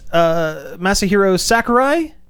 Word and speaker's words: Masahiro Sakurai: Nintendo and Masahiro Sakurai.